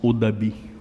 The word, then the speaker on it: Odabi: On prononce Odabi, O-D-A-B-I, qui veut dire racine.